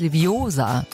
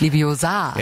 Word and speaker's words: Leviosa: Es heißt Leviosa, nicht Leviosa.